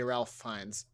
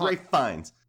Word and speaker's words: Fiennes: Other people have searched how to say Ralph Fiennes. Oh, good. There's a YouTube article on it. Or a link. – Is it an article? – It's Ray. Yes, I was right. It's Ralph Fiennes.